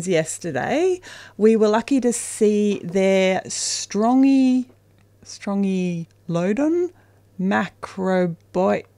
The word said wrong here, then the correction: macrobotrys: Wow, I butchered that. – Strongylodon. – Strongylodon. – Macrobotrys.